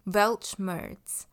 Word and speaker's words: Weltschmerz: 接下来呢，这个字德文的发音是 Weltschmerz，Weltschmerz，英文的发音则会比较偏向 Weltschmerz 或者是 Weltschmerz。